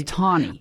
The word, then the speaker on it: Taney: Did I pronounce that correctly? – Yes, it is Taney.